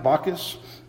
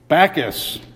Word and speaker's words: Bacchus: Philadelphia was also went through Bacchus? Is that not how you say it? How do you say it? Bacchus.